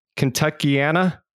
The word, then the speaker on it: Kentuckiana: For those of you listening, Brad is the CEO and principal broker at, and I know I'm going to say this wrong, Kentuckiana Mortgage Group. Is that correct? – That's it. Mm-hmm.